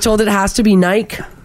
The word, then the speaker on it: Nike: please pronounce it correctly. It's Nike.